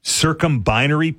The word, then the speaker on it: circumbinary: these are called circumbinary planets, am I correct? – You are correct indeed, you didn't butcher it at all.